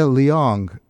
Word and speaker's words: Leong: That is the Dana Long Quintet, or I should say Dana Leong Quintet. I think that's how you pronounce it, from his album Leaving New York.